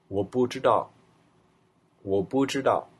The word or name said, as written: buzhidao